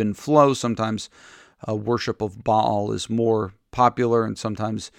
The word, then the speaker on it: Baal: You could call this god Baal. That's the typical way of pronouncing it, but it would be in Hebrew Baal.